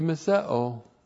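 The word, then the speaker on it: miseo (μισέω): It's an adversative chi, and it should be translated and yet, or however, or but, we'll translate it, and yet he miseos. Not good. Not good to miseo. M-I-S-E long O, miseo.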